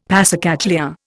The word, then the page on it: passacaglia: Indian